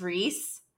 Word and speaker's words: Rhys: One of the main characters, his name is Reese. Okay. But it's spelled R-H-Y-S.